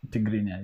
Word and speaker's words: Tigrinya: He's learned two extra languages, Arabic Tigrinya? – Yeah, Tigrinya. You said it right.